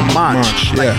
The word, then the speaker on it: Monch: When I first met him, I was like, Feral Monk? He was like, no, Monch. Monch. – I said it wrong, Oh, my bad.